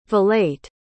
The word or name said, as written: vallate